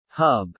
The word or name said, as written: hub